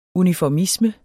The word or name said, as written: uniformisme